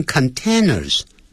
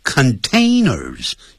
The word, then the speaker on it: containers: containers 不要念成 containers， a 的音 containers，像飞机叫 plane，不要念成 plan、 yeah。